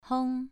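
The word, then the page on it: hong: tone 2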